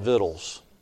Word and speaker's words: victuals: Victuals is a little bit different pronunciation. In the south, we'd call it victuals. I don't know if you just don't pronounce the C or how it works.